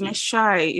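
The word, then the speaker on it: Mai shai: So basically, Mesha. Is it Mesha? Okay, Mesha. They sell bread and egg and tea. – Meshai. Like, okay, it's Meshai. It's pronounced Meshai.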